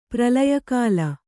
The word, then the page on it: ಪ್ರಲಯಕಾಲ: kannada